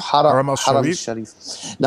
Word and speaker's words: Haram al-Sharif: Haram al-Sharif.